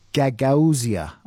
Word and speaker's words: Gagauzia: Which country contains the autonomous region of Gagauzia? I'll spell it for you. G-A-G-A-U-Z-I-A.